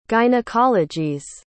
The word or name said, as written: gynecologies